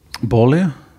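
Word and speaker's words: Boehly: Og noe av det mest fornuftige som um, Bowley har sagt, Bali. Ja. Er det uttales?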